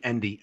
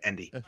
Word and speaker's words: Endi: So when he messaged me, Endy, E-N-D-I, that's my name, Endy. You fucking literally clicked my name to get to me. You can't say it right.